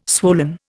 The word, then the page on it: swollen: Indian